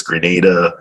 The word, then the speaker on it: Grenada: Like I keep saying, it's not Saint Lucia, it's Saint Lucia, it's Grenada, uh, and, and, you know it's Dominica, so they they can't find it.